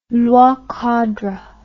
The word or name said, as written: loi cadre